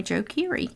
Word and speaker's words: Joe Keery: So yeah, and you said his name right, Joe Keery.